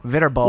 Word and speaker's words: Viterbo: Not much to discuss with their win over, what is it, Viterbo? – There you go. – Viterbo. – Whoa, is that a pronunciation that you just got right? – Yes, I did.